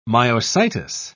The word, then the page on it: myositis: British English